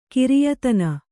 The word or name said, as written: ಖರೆಯತನ